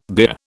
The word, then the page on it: bier: British English